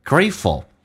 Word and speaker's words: grateful: grateful 感激这个单词 gray 饱满，同时 t 不发音，在音标当中 t 跟 f 是连接在一起，两个辅音在一起，那么只用发后面一个，但气流要阻塞，因为前面是元音叫做 grateful ful，事实上直接在美式发音可以发成 fl，叫 full，叫做 grateful。